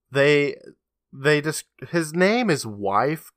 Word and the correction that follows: Waif: Kid, and I don't know if I'm saying that right or not. W-A-I-F? – Oh, Waif. – Waif. Okay, like wafer.